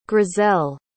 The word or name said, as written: grizel